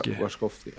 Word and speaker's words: Wachowski: Hur uttalar man deras efternamn? – Något sånt. Wachowski.